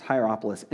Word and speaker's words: Hierapolis: There was Laodicea, there was Colossae, which is the book of Colossians, and then a place called Hierapolis is the way it's pronounced, Hierapolis.